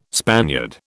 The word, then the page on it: Spaniard: British English